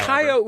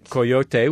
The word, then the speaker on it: coyote: Coyote would be the proper Spanish pronunciation. – Ah. – And coyote would be the American pronunciation. – Classic American. – The classic American pronunciation.